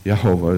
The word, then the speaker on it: Jehovah: And that's how they would spell it, with a J-E-H-O-V-A-H. And the J is not a hard J, it's a Yah. Yehovah is how they would say it. But more accurately, people say it really should be Yahweh in the Hebrew language.